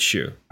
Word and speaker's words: Hsu: for Sue or Shoe. I'm am I, sure am Shoe. I saying that right? I believe. – Is it is it Shoe? – Stephanie I Shoe. I believe it's Shoe.